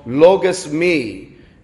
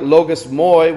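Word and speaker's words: logismoi: What the fathers call logos me. It sounds like logos moi when you actually read it out, because it's L-O-I-L-O-G-I-S-M-O-I, but it's actually pronounced logos me.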